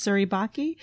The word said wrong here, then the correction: Suribachi: which is a real volcano. – Chi. – Say it. – It's a chi. Yeah. Suribachi.